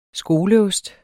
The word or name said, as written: skoleost